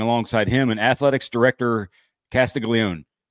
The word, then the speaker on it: Castiglione: But it was odd to me that he didn't use first names and athletics director Castiglione. Like, just too formal for me. – That's how you say it.